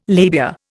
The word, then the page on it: labia: Indian